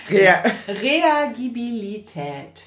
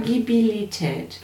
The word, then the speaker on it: Reagibilität: Mit der Betonung ist sie damit so... – Reagibilität. – Reagibilität. – Ja. – Also wie reagieren erstmal? – Ja, genau.